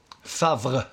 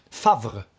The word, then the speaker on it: Favre: Eh, grundades 1865 av Georges Favre eh, Jagot. Favre. Ja, precis. – Ursäkta uttalet. Nej, men- tvärtom. Mm. Snyggt uttal. Ja, tack. Favre.